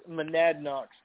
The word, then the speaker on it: Monadnock: That's pronounced, it's Monadnock Speedway.